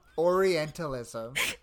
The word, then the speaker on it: Orientalism: Orientalism. Because the thing is, Orientalism sounds wrong. Orientalism sounds half. Orientalism sounds like it's missing a syllable, but that's what it is.